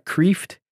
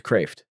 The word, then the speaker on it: Kreeft: But his last name is spelled Kreeft. So it looks like Kreeft, but it's Kraft, Peter Kraft.